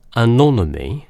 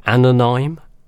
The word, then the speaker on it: Anonyme: Out on Agent Anonymy, or Anonyme, it's spelled A-N-O-N-Y-M-E.